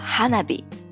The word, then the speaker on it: 花火: 花火呢，在日文的发音中是叫 hanabi，其实就是我们中国所说的烟花。